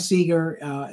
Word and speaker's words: Segar: I mean, you know, think Pete Segar, you know, Bob Segar, and so on. They're spelled differently, but we're all pronounced the same.